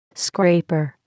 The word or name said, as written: scraper